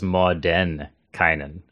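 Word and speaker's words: Mordenkainen: So Mordekainen, I just realized it's Mordekainen. Okay, so I already am improving my pronunciation here.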